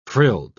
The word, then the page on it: frilled: British English